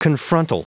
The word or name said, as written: confrontal